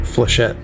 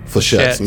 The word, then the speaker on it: flechettes: And they launch these nano fleshites. Am I saying that right? – Flechette. – Flechettes. Nano flechettes.